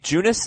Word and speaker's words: Junis: Jake Junis, is it, Heath? Did I pronounce that right? – Yeah.